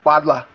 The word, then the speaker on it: bottler: Say bottler again. That's how you say it. It's like you say it really like with a strong Australian accent when you say it, like bodla.